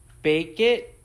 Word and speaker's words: Bakkt: The groundbreaking announcement on Friday revealing that new platform Bake It is spelled B A K K T.